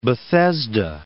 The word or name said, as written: Bethesda